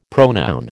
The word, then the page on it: pronoun: American English